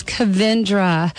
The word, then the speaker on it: Kavindra: And today my guest is Jeffrey Armstrong, also known as Kavindra.